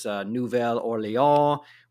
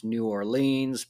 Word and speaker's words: New Orleans: It is going to be interesting jumping back and forth, because of course we have the famous city, which in French is Nouvelle Orléans, which most Americans pronounce New Orleans, but which they would pronounce Nolens.